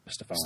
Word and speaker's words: Stephon: You like him so much you mispronounce his name, Stephon. – Stephon Gilmore, yeah.